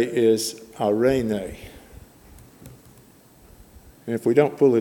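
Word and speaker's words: arene (εἰρήνη): The Greek word, E-I, is pronounced ah. And then R, the E is pronounced like an A in both, so it's arene in Greek.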